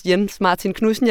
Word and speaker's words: Jens Martin Knudsen: Øh, jeg taler med ejeren af, af, af en fiskefabrik, øh, på dansk udtales hans navn Jens Martin Knudsen.